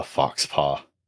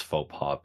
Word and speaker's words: faux pas: I know it's pronounced faux pas, but it's part of the vibe is that you're supposed to pronounce it wrong.